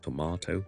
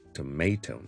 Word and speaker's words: tomato: 大家都知道 tomato 和 tomato 呢这个是英式和美式 ,tomato, 英式 ,tomato, 美式。